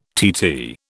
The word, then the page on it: TT: British English